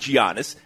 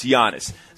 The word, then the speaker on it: Giannis: And to that point, people still butcher his first name. They're pronouncing it with a G. And how many times has Giannis had to come out and say it's not Giannis, it's Giannis.